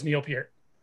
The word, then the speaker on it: Neil Peart: So I wonder whose name is more mispronounced, yours by Ed or Neil Peart by everybody. – Yeah. – Because forever, I think I just learned it was Neil Peart, recently.